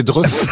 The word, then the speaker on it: Dreux: Vous le prononcez mal, mais c'est Dreux.